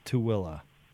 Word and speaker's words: Tooele: So if a business calls you from say Tooele, you guys know how to pronounce Tooele we know how to pronounce Tooele, and we've we we've been to Tooele.